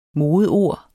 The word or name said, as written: modeord